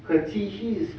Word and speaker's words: катехизис: Она называется либо это, либо ито. Соответственно, читается как либо э, либо и. Катехизис или катехизис. Ну ладно, но это еще не все.